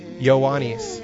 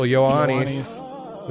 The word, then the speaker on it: Ioannis: So John is actually Yanni. Well, that's Johnny. Like that shitty singer is Yanni. I'm Ioannis. – Okay. – Is the actual Ioannis.